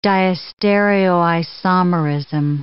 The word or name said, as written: diastereoisomerism